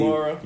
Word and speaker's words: Kamara: Kamara.